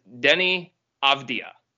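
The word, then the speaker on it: Deni Avdija: It is Denny Avdija. You do not pronounce the J.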